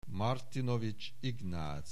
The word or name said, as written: Martinovics Ignac